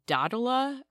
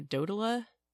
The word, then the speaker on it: Dodola: It's, like, a dodola or a dodola. It's D-O-D-O-L-A.